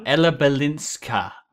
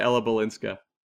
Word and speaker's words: Balinska: Ella Balinska. A at the end, my friend. – My apologies to Ella Balinska.